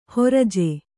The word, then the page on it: ಹುರ್ಜಿ: hurji